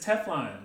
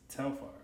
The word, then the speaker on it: Telfar: She's like, oh, it's Teflon. And they're like, no, it's Telfar.